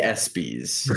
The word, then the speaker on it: ESPYs: How do you pronounce that? – The ESPYs? – They do. – The ESPYs. – Yes, thank you.